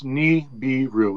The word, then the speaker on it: Nibiru: It's Nibiru, which means planet of the crossing. Nibiru. Yeah, I don't really use the term, but that's how it's pronounced.